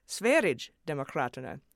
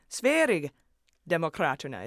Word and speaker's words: Sverigedemokraterna: So I tried again with Sverigedemokraterna, and now you've all come back saying, no, soft G like a Y sound.